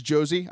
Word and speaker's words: Jozy: And his name actually is Jozy. I thought it was Jose for a long time. People were just mispronouncing it. His first name is J-O-Z-Y. It's Jozy.